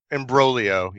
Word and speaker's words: Imbroglio: It looks like it is Imbroglio. Yeah, look, just looking it up. There's no G.